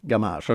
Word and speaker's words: Gamache: Is it usually I pronounce it uh, g- gamage? – Gamage. Okay. Okay. Yeah. yeah. – Gam- gamage would be maybe maybe too French.